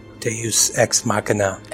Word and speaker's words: Deus ex machina: Like, this is the Deus Machina. How do you say that? – Deus Ex Machina.